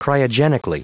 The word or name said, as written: cryogenically